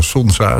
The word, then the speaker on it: Sonza: Sonza, zeg ik dat goed? Ja, Sonza, het doet me denken aan de zon.